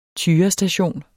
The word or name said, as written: tyrestation